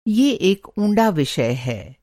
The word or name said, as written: ऊँड़ा